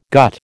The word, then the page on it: gut: American English